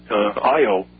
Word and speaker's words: Io: And there's experimental evidence right now today that the moon Io or Eo, depending on your pronunciation, Io of mm-hmm.